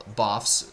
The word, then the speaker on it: BOF: which they call BOFs. It's B-O-F, stands of Birds of a Feather, but they pronounce it BOF.